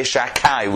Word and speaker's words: Shakai: We don't pronounce it Shin Dalet Yud, and we don't read it. We say Shakai. We substitute the Dalet for a Kuf, or the D for a K sound.